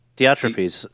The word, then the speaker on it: Diotrephes: Diotrephes.